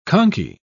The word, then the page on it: conky: British English